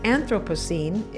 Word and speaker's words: Anthropocene: She says that Anthropocene is the preferred pronunciation rather than Anthropocene.